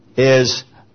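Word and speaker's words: miano (μιαίνω): This is defilement, and the root word is miano, M-I-A, and then I-N-O. M-I-A-I-N, and again, long O.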